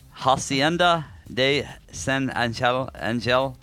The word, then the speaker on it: Angel: Well, I think you say Angel. – Angel, yeah. – Angel is how you pronounce it in Spanish.